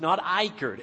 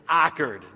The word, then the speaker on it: Icard: You go back to my hometown, back in Ickard, North Carolina, and it's Ickard, and it's spelled I-C-A-R-D. You guys get that? It's not Ickard. It's Ickard.